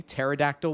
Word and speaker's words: pterodactyl: Please don't make it a silent P. Pterodactyl will not count.